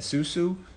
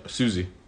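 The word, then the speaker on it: Siouxsie: How do you say that? Susu? – No, Susie. – Susie? – Yeah. But, you know, for those who are not, it's spelled Sioux something. – S-I-E.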